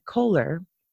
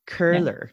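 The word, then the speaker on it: Köhler: And I'll try to say it again. Curler. Yeah. Almost like an Curler. E-R-H kind of a sound. Curler. – Yeah, it has an umlaut.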